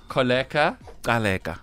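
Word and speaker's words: Kholeka Gcaleka: Say her name very slowly one more time for the people who can't click with their mouths. – Advocate? – No, we can say that. – Koleka. – Koleka. – Kaleka. – So it's Koleka Kaleka. – I hope I'm saying it right. – It's like a little rhyme. Holly, please just fact check this man. – That is correct.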